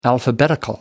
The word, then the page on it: alphabetical: American English